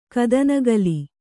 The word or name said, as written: ಕದನಗಲಿ